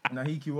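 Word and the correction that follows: Nahki: Wells is my... Or whatever he pronounces his name. – Naki Wells.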